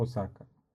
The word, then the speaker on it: Осака: Ну, дальше это Осака, Япония. – Осака. – Здесь нет ударений, так что Осака.